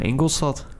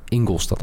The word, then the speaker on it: Ingolstadt: Waarom spreek je al die gekke namen zo gek uit? – Hoe spreek jij dat? – Van Ingolstadt. – Ingolstadt, oké. Okay.